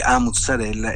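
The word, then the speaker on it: 'A mozzarella: La, la, lingua napoletana prevede la A alla fine della parola mozzarella, invece noi l'abbiamo abbreviata affinché dovunque si pronunciasse così come si pronuncia a Napoli, con, appunto con la, eh, la A troncata quindi A mozzarella è inequivocabile, la pronunceranno tutti benissimo.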